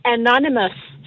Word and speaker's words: Anonymous: Det hedder Anonymous, så det er NN og M til sidst så. A-N-O-N-Y-M-O-U-S, så er det Anonymous.